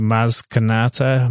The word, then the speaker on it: Maz Kanata: So when Finn and Poe talked to Maz Kanata, am I saying that right? – Yeah.